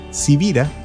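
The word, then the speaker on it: Sivira: Civira, que es mi apellido y que no es tan común, se escribe con S de Samuel y con B de Victoria.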